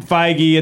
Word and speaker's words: Feige: Feige? – Feige.